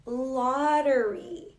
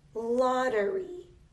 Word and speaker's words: lottery: We don't say lottery. We say lottery. We pronounce this double T like a D, and it is that way for almost every double T sound. Not all of them, but almost all of them. So this word is pronounced as lottery. Lottery.